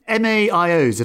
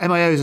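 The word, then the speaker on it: MAOIs: And so these MAIOs, if that's right, MAIOs and tricyclics, am I pronouncing them right? – Yes.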